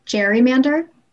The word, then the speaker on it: gerrymander: He pronounced his last name with a hard G, but his namesake verb, a type of manipulative political practice, is typically pronounced with a soft G. – Gerrymander.